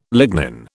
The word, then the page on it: lignin: British English